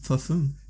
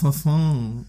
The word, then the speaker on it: Parfum: Das ist, ich meine, nicht mal im Französischen wird doch Parfum ausgesprochen, da wird doch ein U wie ein Ü ausgesprochen. Wenn es jetzt ENT wäre, dann wäre es Parfum.